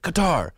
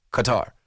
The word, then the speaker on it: Qatar: And so I just watched that and I saw the guy announce it and he said, Qatar. So it's not cutter, like cut fastball, cutter. It's cut, but tar. Like, so when you're pronouncing it, C-U-T-T-A-R, Qatar. Not Quatar, not Qatar.